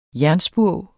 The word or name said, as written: JERNSPURV